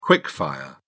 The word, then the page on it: quick fire: British English